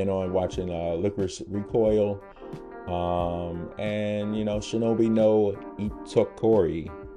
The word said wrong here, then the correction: Shinobi no Ittoki: No, wait, I might have said that wrong. Shinobi no Ittoki. Ittoki. There it goes.